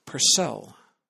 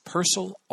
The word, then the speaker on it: Purcell: By the way, you will hear some people say Purcell. Resist, my friends. It's Purcell all the way. I don't mean to sound too absolute on the subject. There's a little debate over the man's pronunciation, but I am in the Purcell camp solidly.